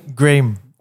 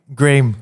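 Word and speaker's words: Graeme: Graham. – One more time. – Graham. – And how do you spell that? – Yeah. It's G-R-A-E-M-E.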